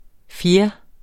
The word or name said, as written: fjer